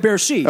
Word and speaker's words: Bercy: Is it Bercy? Is that how you pronounce it? Mm-hmm. When July 4th in 2013-14, Bruce played in Paris. Where do you remember? – He played Bercy.